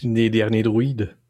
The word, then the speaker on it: Les Derniers Druides: Les Derniers Druides.